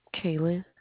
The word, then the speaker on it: Kaylen: Kaylin.